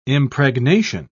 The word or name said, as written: impregnation